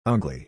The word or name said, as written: ugly